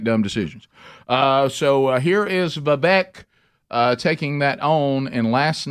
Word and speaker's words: Vivek: Last night, the highlights, Vivek, is that how you say his name? – Yep. Vivek Ramaswamy.